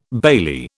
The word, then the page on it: bailie: British English